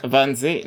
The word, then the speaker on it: Wannsee: Je sais pas comment on prononce vraiment. – Wannsee. – La conférence de Wannsee, elle a été.